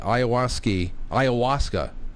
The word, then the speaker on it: ayahuasca: You got to hear Aaron Rodgers talk about the ayahuasca. It's spelled like Iowa with a ska at the end.